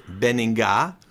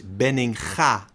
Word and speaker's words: Benninga: Like you pronounce my name, which is not Benninga, but the real Dutch pronunciation is Benninga, the ugly G from in Dutch.